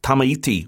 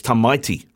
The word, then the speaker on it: Tamaiti: Of course, on the bench, one of the reserve props plays for the Crusaders and Canterbury, I said. Uh, Tamaiti Williams, but it's Tamaiti, Tamaiti Williams.